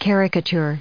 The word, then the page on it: caricature: noun